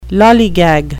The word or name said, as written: lollygag